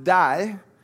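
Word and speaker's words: die (δεῖ): It's pronounced die, and it means it is necessary.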